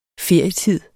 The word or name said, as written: ferietid